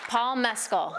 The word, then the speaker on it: Mescal: It's an Irish. It's not going to be Mescal. Mescal. It's not. You got to say it like if you were saying an Irish accent.